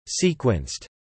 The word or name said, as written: sequenced